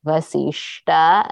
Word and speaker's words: Vasistha: So I try to say Vasishta as much as possible, pronounce it that way, but it had become a habit because that's what I thought it was.